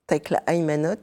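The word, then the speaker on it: Tekle Haymanot: Takla, Emano et Evostateios. – Tekla Aymanot et Eostateos.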